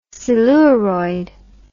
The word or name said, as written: siluroid